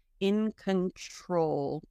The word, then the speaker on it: control: Okay, for the pronunciation, in con, not con, but con, in, it's almost like a, it's a schwa, but if you want to visualize it like a U, in control, tr, like tree.